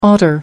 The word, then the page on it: otter: American English